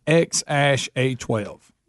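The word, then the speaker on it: X Æ A-12: It's spelt weird, but it's pronounced X-A-12.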